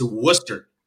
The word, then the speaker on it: Worcester: Oh, I'd say about an 8 on, it's Worcester. Like Worcester. It's like Worcester sauce without the sauce.